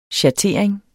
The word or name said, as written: schattering